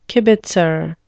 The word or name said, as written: kibitzer